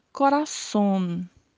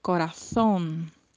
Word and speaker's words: corazón: Nós já aprendemos antes que o Z no espanhol tem som de S. E então, uma das possibilidades de pronúncia seria falarmos corazón, corazón. Mas há uma particularidade no espanhol da Espanha e de algumas outras regiões onde o Z tem um som um pouco mais abafado. E ele pode ser pronunciado com a língua entre os dentes. E aí falaríamos corazón, corazón.